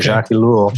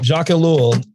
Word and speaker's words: Jacques Ellul: Jacques Ellul, is that how you say it? That's how I say it, okay. Jacques Ellul. Jacques Ellul, and why he is such an important person to listen to, simply because you quote him quite a bit.